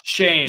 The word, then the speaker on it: Schoen: I think it's Shane. – Shane, my bad. And it looks like Schoen, but yeah. Shane. – Yeah, they wanted Shane, who the G-Man got, but he didn't want to come because he wasn't happy with the Bears.